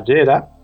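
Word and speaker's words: Jera: Porque la runa que ha sacado aquí, la manita inocente, es la runa que se llama Yera. Se escribe con J, aunque se pronuncie como una Y.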